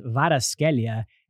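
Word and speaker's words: Kvaratskhelia: It's pronounced Varaskelia, and they're calling him Varadona Ooh, it's a lot of pressure.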